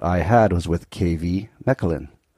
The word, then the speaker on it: Mechelen: Mechelen, yes, Mechelen.